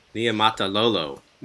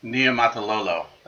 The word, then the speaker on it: Niumatalolo: Niumatalolo. – Niumatalolo.